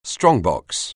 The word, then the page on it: strongbox: anglais